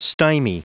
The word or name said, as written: stymie